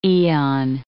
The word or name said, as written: aeon